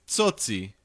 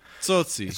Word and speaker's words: Tsotsi: I will tell you, it's Tsotsi. – Tsotsi, is that how you say it? – Tsotsi.